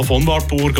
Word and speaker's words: von Wartburg: Habe ich es richtig ausgesprochen? Ja, von Wartburg oder äh, ja, spielt echt keine Rolle.